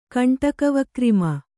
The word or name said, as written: ಕಂಟಕವಕ್ರಿಮ